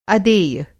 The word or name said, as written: अदेय